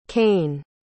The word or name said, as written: Kane